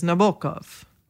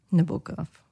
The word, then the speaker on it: Nabokov: So depending on where you're from, you pronounce his name differently. And he actually did a whole pronunciation guide to his own name. – Okay. – The closest approximation is Nabokov.